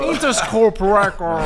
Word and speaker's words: Interscope: Abbiamo firmato questo mega contratto con la Interscope Record, ma... – Rare, wow, wow, wow, wow, English! – Aspetta, dammi sta patata! Dammi sta ma patata! l'ha detto giusto! Interscope Record!